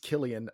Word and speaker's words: Cillian: But I think it's Killian. I'm not trying to be that guy and correct you, but I've always thought it was weird because it's definitely spelled like Cillian, but multiple people have corrected me that it's Killian.